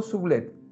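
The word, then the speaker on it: Soublette: Gastón, Sublet. – Sublet se escribe S-O-U, B L-E-T-T-E. E. Gastón Sublet.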